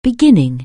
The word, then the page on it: begin: anglais